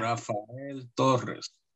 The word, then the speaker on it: Rafael Torres: I love it that you pronounce my name in Espanol. – Yeah, Rafael Torres.